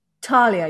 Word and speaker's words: Thalia: Talia. You don't say the H. – No. – No. That's what always confuses me. I'm never sure if it's Thalia or Talia. But it, well, isn't that beautiful? – I also have Narcissus Thalia, and I've also never known how to say it. So let's all just fall in line and make it a silent H.